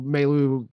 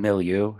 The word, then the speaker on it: milieu: So now I've got that kind of cultural milieu. Did I pronounce that term right? I don't know. – Milieu. – Milieu. Yes. Thank you.